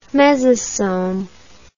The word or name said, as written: mesosoma